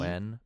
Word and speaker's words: Pederson: Pederson, but it's said like Peterson, like a T.